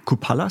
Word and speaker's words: Kupala: Kupala, ist das richtig ja.